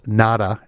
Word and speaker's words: Nauta: work at Mar-a-Lago to a person who's, I think his name is pronounced Nauta, N-A-U-T-A.